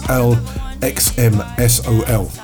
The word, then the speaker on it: Slxm Sol: Then that was followed by Slxm Sol, and I think it's pronounced Slxm Sol. It's Slxm Sol.